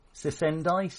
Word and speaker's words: Cicendai: It is the Sisendai, C-I-C-E-N-D-A-I. – C-I-C-E-N-D-A-I. Ah, okay, I had it as Sosendai.